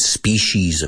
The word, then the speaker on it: species: Different species of animals. Careful with the pronunciation. Species, aunque no lleva SH, suena a SH.